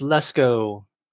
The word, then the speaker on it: Lesko: Today, I'm talking with Precious Lesko. Did I say your name right? – Yes. Yeah, that's correct. Well, it's technically Leshko, but nobody says it like that.